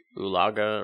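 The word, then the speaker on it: Oologah: Yeah, that's how I would say it. Oolaga or Oolaga.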